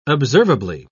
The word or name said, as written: observably